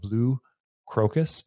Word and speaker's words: Blue Crocus: Currently, he is an owner of Blue Crocus.